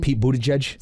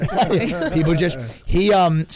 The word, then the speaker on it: Buttigieg: Say his name again. – Pete Buttigieg. – Say it one more time. – Why, am I not saying it right? – No, you are. – What? Pete Buttigieg.